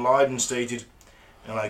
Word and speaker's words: Lydon: Former Sex Pistols vocalist John, how is it pronounced? It's Lydon.